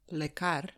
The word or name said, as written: lekar